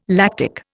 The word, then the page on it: lactic: Indian